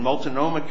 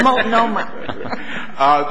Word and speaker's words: Multnomah: It's that an individual judge in Multnomah County, Oregon, I hope I pronounced that right, Close. But not close. Multnomah.